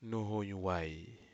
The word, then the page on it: nohoyuwai: Aneityum